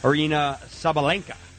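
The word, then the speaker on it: Aryna Sabalenka: before Gauff's fellow American Madison Keys goes against Arena Sabalenka.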